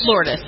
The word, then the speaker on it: Lourdes: And isn't that how you pronounce the name, Lourdes? – Lourdes. Mm-hmm.